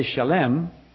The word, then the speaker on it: Shalem: But the last part, we say Salem. It's really Shalem.